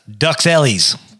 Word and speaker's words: Duxelles: It's really, guys, pronounced Duxelles.